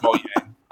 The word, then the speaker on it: Mojang: Wait, is it really pronounced Mojang? It's not Mojang? – Yes. Yes, it's pronounced Mojang.